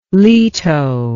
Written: leto

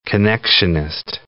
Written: connectionist

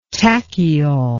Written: tachiol

